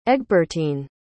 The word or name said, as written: egbertine